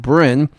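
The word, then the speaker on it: Brun: Let's hear Avraham Brin. It's B-R-U-N. I believe I've been told that it's pronounced Brin, and this is Machnisei Rachamim, Avraham Brin.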